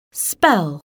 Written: spell

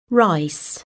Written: rice

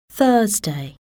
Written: Thursday

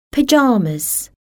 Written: pyjamas

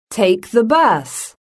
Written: take the bus

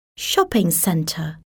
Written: shopping centre

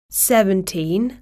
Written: seventeen

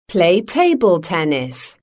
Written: play table tennis

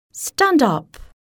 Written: Stand up!

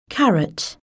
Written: carrot

